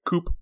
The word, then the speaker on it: coupe: So when the government does a coup, you don't pronounce the P. But when you try to put it in a coupe glass, you pronounce the P.